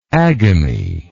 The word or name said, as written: agamy